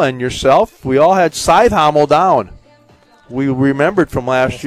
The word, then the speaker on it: Cajthaml: C-A-J-T-H-A-M-L. Scythe Hommel is how they pronounce it.